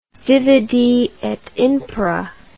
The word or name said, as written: divide et impera